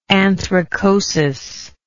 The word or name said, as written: anthracotic